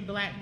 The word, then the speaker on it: Blaque: My first business venture, Black Book, started off as concierge. – Spell it. – B-L-A-Q-U-E.